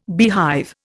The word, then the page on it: beehive: Indian